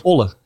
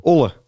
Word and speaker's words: Zwolle: Olle, zeggen ze. Olle. Ja, Zwolle wordt niet uitgesproken.